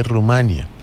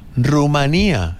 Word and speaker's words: Rumanía: No se dice Rumania. Se dice, y me lo dijo un rumano aquí en Madrid, Rumanía, y el señor es rumano.